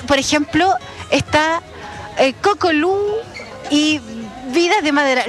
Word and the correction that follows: Cocolau: ¿Lo pronuncié bien o es Cocolau? Es Cocolau. ¿Cómo están, chicas? – Hola, bien.